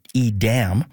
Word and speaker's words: Edam: Most people probably just say Edam, but Pam confirmed it's more Edam.